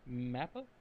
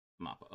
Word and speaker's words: MAPPA: If Japan was going to pronounce it as written, MAPPA. – MAPPA, okay. Square Enix is... Most people in the West say MAPPA, so whatever.